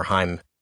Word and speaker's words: Haim: If you ever want to, it's pronounced Haim, but people think they're called Haim or know them as Haim.